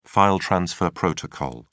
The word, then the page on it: file transfer protocol: British English